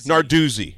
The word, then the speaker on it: Narduzzi: Narduzzi.